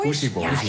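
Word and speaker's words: Poëzieboys: En mag ik een warm applaus voor Joost Ome en de Poëzie Boys. Fijn dat jullie bij ons zijn het komende uur. Zeg ik het goed, Poëzie Boys? Of is het Poesie Boys? – Poesie Boys.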